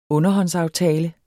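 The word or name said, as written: underhåndsaftale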